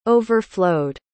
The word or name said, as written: overflowed